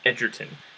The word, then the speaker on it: Egerton: I listened to an interview beforehand, so I made sure to not mess this up this time. It's Egerton. That's what he says. – Egerton? – Yes.